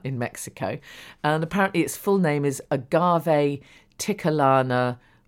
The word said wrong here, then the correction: Tequilana: I wouldn't have said that right, will I? Tequilana Blue Weber.